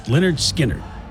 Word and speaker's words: Lynyrd Skynyrd: Today in rock history, Lynyrd Skynyrd released their self-titled debut album, pronounced Lynyrd Skynyrd,